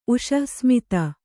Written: ಉಷಃಸ್ಮಿತ

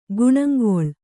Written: ಗುಣಂಗೊಳ್

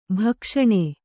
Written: ಭಕ್ಷಣೆ

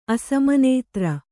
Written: ಅಸಮನೇತ್ರ

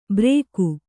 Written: ಬ್ರೇಕು

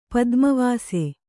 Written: ಪದ್ಮವಾಸೆ

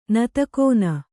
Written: ನತಕೋನ

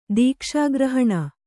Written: ದೀಕ್ಷಾಗ್ರಹಣ

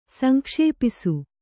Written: ಸಂಕ್ಷೇಪಿಸು

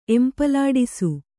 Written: ಎಂಪಲಾಡಿಸು

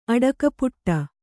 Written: ಆಟಕಪಟಿ

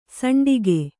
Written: ಸೋಂಟಿಗೆ

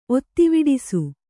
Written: ಒತ್ತಿವಿಡಿಸು

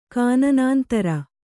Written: ಕಾನನಾಂತರ

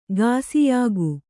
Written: ಗಾಸಿಯಾಗು

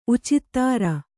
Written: ಉಚಿತ್ತಾರ

